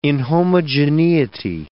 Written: inhomogeneity

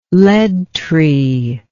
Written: lead tree

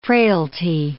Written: frailty

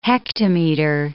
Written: hectometer

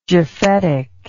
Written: japhetic

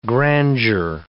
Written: grandeur

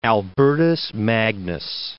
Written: Albertus Magnus

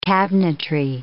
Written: cabinetry

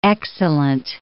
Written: excellent

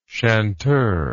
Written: chanteur

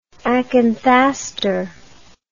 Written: acanthaster

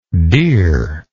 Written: deere

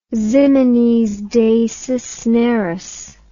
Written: ximenes de cisneros

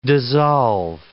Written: dissolve

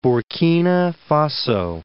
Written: Burkina Faso

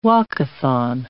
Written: walkathon

